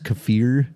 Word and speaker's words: kefir: The new dairy thing I keep hearing about is, and I was calling it kefir, but apparently it's pronounced kefir, but it's like a...